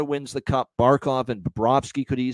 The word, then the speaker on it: Bobrovsky: We've been robbed of Charles not being unable to hear Charles Barkley say Sergei Bobrovka. pronouncing Sergei Bobrovsky.